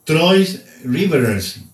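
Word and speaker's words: Trois-Rivières: Mario Feliciano, el receptor de los Indios de Mayagüez, tuvo una gran temporada con Mayagüez y pues está allí, ahí con Troy Rivers. ¿Cómo se dice eso, Sandro? – Sí, así mismo como lo indicaste, ya que eso fue francés, ya que ese equipo es de la Frontier League, que está en Canadá. Okay. Y lo pronunciaste excelentemente.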